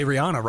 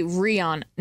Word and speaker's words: Rihanna: By the way, almost all of us, I think, say Rihanna wrong. She's... – I think it's technically Rihanna. No. – I think it's Rihanna.